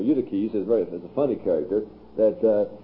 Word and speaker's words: Eutyches: 449, Eutychius, well, Eutyches, as he should be pronounced, Eutychianism, Eutyches, he was a rascal.